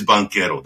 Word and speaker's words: Banchero: It's an Italian. What it means, it's a banker in Italian. So I guess he's got some Italian roots. So it is Banchero.